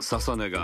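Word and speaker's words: 楽音: 続いては現在 18 歳のシンガー、モデル、インフルエンサー、ササネが TOKIO HOT 100 初お目見え。楽しいに音と書いてササネと読みます。ササネ、もしもし、誰？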